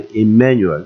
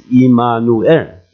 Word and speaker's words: Immanuel: 英文的话你很可能会翻译 Emmanuel 这样的发音。这个正确的希伯来文的发音是 Immanuel，这个到底什么意思我们都知道。